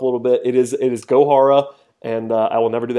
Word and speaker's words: Gohara: I comically asked the Braves for a pronunciation update on Luis Gohara, because I pronounced it Johara, and I'd heard that from a couple of people. I was wrong, they were wrong, and it is Gohara with a hard G.